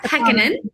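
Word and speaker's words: Pekkanen: Sarah Pecan. I always say her name wrong. Sarah Pekanen, right? – Yes.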